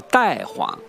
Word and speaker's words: 大黄: 比如说有一个中药叫大黄，原来就叫带黄，它读音呢又可以读个太，跟太也相近，叫太子啊、太君啊，这个呢都表示一种尊重，这个意思。